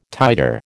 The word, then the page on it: titre: American English